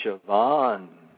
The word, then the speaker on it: Siobhan: How do you pronounce her first name? – She is Siobhan.